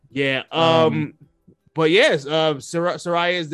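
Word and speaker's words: Saraya: Soraya, how do you say Soraya? Is it Soraya? – Yeah, Soraya.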